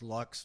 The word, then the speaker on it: Luyckx: Yeah, so most people will, or at least most people around Dallas have seen this very, very weird last name, L-U-Y-C-K-X. It's actually pronounced Lux, very, very simple, but it gives it away.